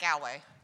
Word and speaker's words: Galway: Galway. – Galway. – Galway. Gull, right? Not gal. – Not Galway. – Not Galway. – Galway. Like, Gull. She said it was like gallbladder.